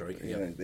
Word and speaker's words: Perestroika: So we say communism; it's still alive, but they opened up the Eastern Wall, and Perestroika, is that how you say it? – Perestroika. Yeah.